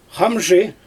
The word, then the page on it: xamʒɯ: Xyzyl